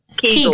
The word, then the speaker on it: Kegel: Kegel or kinkle? – Kegel. Kegel. It's Kegel. Kegel. – Okay, it's not kinkle. – That's another thing. – So there's Kegel balls.